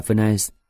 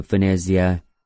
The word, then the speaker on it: Venezia: Cậu ta bảo tôi đừng gọi là Venice theo kiểu Anh Mỹ, hay gọi thành phố của cậu là Venezia.